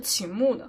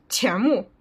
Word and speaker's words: 钱穆: 就是中国历史精神，推荐大家看，是秦牧的。钱穆，我又发音错误，不好意思。